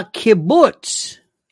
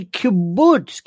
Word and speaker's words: kibbutz: Rule number one, if you don't have somebody who is on your staff to say, excuse me, you're mispronouncing words. Steve Doocy, a kibbutz. A kibbutz is a unit, a living, housing, socialist, whatever union, a kibbutz, kibbutzim, you know, plural, but anyway, a kibbutz.